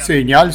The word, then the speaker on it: Signal: non so come si pronuncia in italiano. – Signal, sì.